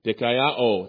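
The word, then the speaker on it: dikaiō (δικαιόω): The Greek word that James uses for justified is dikaiō. It's spelled D-I-K-A-I-O-O.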